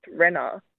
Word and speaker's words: Raina: So her name was spelled R-A-I-N-A. So I thought her name was Raina. – Yeah. – And then about a year and a half later, she told me her name was actually pronounced Renna.